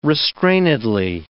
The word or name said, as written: restrainedly